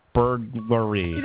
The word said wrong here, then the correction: burglary: You're missing a whole syllable there. – Burglary.